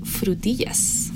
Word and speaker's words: frutillas: we call strawberries frutillas.